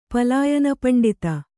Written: ಪಲಾಯನಪಂಡಿತ

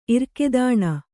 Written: ಇರ್ಕೆದಾಣ

